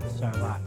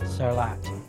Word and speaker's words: Sarlat: They don't pronounce the T here. If you pronounce the T, they give you a bad look. So I'm in the habit of pronouncing Sarlat.